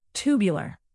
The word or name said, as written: tubular